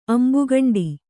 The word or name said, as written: ಅಂಬುಕಂಡಿ